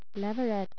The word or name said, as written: leveret